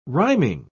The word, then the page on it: rhyming: British English